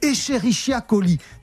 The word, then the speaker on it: Escherichia coli: En fait, c'est Escherichia coli.